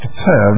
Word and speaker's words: per: Now, that's an Egyptian symbol, and it's pronounced per, and it means a house.